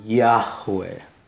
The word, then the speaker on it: Yahweh: Y esto junto, hasta el día de hoy, se cree que probablemente se pronunciaba Yahweh, y esto es el nombre sagrado de Dios.